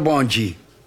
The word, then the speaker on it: bonde: Sí, bueno, pero no por la pronunciación en portugués, la gente empezó a llamar bonde, pronunciado bonji.